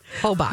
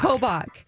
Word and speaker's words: Hobach: That is the name. Hobach. – Hobach. – I said it wrong earlier.